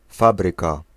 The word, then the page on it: factory: /ˈfaktəɹi/; /ˈfæk.tə.ri/; /ˈfæk.tri/